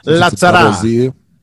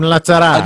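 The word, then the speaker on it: Lazzara: It pronounced Adam Lazara? Lazara? – Lazara. Adam Lazara.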